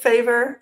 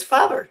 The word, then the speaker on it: Faber: So we've been saying it Faber, and we've been saying it wrong all the time because when we went to Austria, we discovered it is Faber. So you're actually correct.